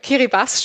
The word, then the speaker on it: Kiribati: Kiribati spricht man es aus in der Landessprache.